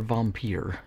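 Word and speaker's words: Vampyr: So 1932's Vampire, or Vampire, if you want to pronounce it like all fucking fancy and European,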